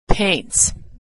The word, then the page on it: paints: ending s